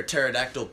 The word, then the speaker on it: pterodactyl: Because the pee is silent. Those of you who don't know, you spell pterodactyl with a P at the beginning of it.